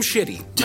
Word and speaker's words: Shitty: Is that an S-C-H? Yeah, what is that? – Oh, the spelling always confuses people. So it's S-H-I-T-T-Y.